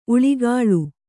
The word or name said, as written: ಊಳಿಗಾಳಿ